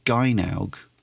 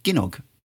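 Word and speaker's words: Gynoug: Now, I've always said Gynaug, but looking at the pronunciation on Wikipedia, it seems that maybe I should have been saying Ginnog all these years, which possibly makes more sense.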